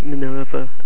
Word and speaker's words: Minerva: It's apparently pronounced the same way that Minerva is pronounced from Roman mythology.